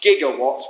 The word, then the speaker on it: gigawatts: Knowing all of that is like knowing that lightning will strike the clock tower at precisely 10:04 p.m., releasing 1.21 gigawatts with a hard G of power.